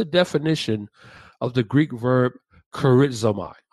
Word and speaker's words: charizomai: Now, the word eucharistos is actually derived from the Greek verb charizomai, which is spelled C-H-A-R-I-Z-O-M-A-I, charizomai. Mm-hmm.